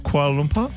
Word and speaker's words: Kuala Lumpur: Is it Kuala Lumpur? – Yeah, you said it right. – Kuala Lumpur.